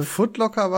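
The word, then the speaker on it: Foot Locker: Footlooker, wie ist die Aussprache? – Footlocker war schon richtig.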